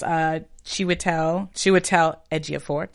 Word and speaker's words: Chiwetel Ejiofor: Chiwetel Ejiofor. Did I say it right? Did I get it? – Yeah, got it.